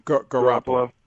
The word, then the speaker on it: Garoppolo: I really like McCarron or Garoppolo. Is that how you say it? – Garoppolo.